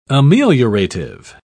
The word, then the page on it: ameliorative: British English